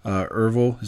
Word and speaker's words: Ervil: But the whole story, I mean, kind of the headliner is that your father, Ervil, is that how you pronounce it? – Yes.